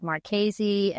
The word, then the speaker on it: Marchese: And I said, Marchese.